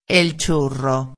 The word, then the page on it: el churro: espanhol